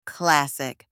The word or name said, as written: classic